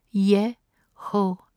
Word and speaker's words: Jehovah: In Keter, the God name is Eheye, spelt E-H-E-I-E-H. It is pronounced Eheye. It means I am. Chokmah, the God name is Yehovah, J-E-H-O-V-A-H, pronounced Yehovah, meaning the Lord God. Bina, the God name is Yehovah Elohim, spelt J-E-H-O-V-A-H, E-L-O-H-I-M, Yehovah Elohim.